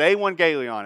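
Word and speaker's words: euangelion (εὐαγγέλιον): EU, you spell that euangelion. Sometimes it's euaggelion, but euangelion is how you say it.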